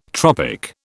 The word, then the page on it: tropic: British English